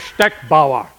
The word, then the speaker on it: Steckbauer: You say my name right. In America, they call me Steckbauer. My name is Steckbauer. It's German.